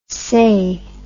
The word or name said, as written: sae